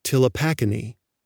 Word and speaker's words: Ptilopachinae: Recall that one subfamily we talked about is called Ptilopachinae. That word has a silent P, so it begins with PTILO.